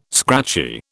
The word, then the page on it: scratchy: British English